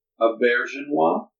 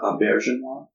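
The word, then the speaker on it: Auberjonois: So I was looking up how to pronounce his name, and I believe it's Auberginois. – A bear. – Yeah, Auberginois. – Auberginois. – But other people have said, I always pronounced it like Arby sandwich dipping, like the Arby sandwich dipping sauce, and I'm like, oh shoot.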